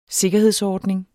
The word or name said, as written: sikkerhedsordning